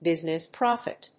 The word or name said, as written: business profit